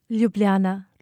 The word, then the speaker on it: Ljubljana: Ljubljana.